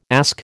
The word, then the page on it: ask: American English